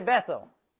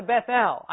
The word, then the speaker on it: Bethel: So they went down to Bethel." I pronounce that the English way. It should be Bethel, because El refers to Jehovah.